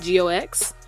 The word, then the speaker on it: Geox: This sneaker, new by G-E-O-X. Is how it's spelled, but it's pronounced G-O-X. Nebula. The G-O-X Nebula sneaker is retailing at $150.